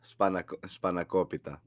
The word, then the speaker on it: Spanakopita: Spanakopita. How do you say that? – Spanakopita.